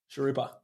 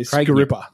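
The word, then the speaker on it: Sghirripa: Sharupa. – It's for your feet. – You sound normal now. Yeah, buddy. There we go. It's S-G-H-I-R-R-I-P-A. – I'm pretty sure I've heard him say Skaripa.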